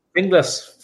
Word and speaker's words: Finglas: How did you go from is it Finglas? Is that how you say it? – Finglas. Finglas is a suburb on the north side of Dublin.